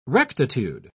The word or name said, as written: rectitude